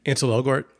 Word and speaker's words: Ansel Elgort: Ansel Elgort, yeah.